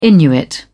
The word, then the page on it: inuit: British English